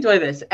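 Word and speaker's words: Duyvis: but I believe it's Corinne Divis. – I think it's Doivis. I looked it up because I had the exact same problem. – Yeah. And so, Corinne Doivis.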